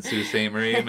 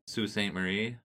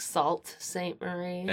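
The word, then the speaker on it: Sault Ste. Marie: I will recommend the Sioux Brewing Company in Sioux St. Marie, Michigan. – Nice. – And as weird, it's spelled Sioux St. Marie. Only in Michigan would you spell that S-A-U-L-T. – Like Salt St. Marie. – And then S-T-E for Saint. They never spell out Saint. It's always S-T-E.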